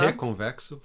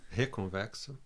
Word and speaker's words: Reconvexo: Reconvexo. Reconvexo. That's the way we say it in Rio. In Rio. Rio de Janeiro. So, Reconvexo. Yes. – So, just in case you go and Google it, folks, it's spelt with an R, even though the pronunciation is Reconvexo, if we're going to be authentic, right? – Right.